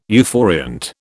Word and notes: euphoriant: British English

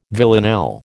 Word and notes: villanelle: American English

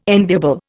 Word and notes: enviable: Indian

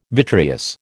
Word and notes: vitreous: American English

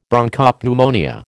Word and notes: Bronchopneumonia: American English

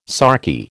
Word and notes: sarky: American English

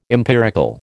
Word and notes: empirical: American English

